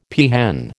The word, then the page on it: peahen: American English